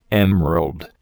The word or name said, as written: emerald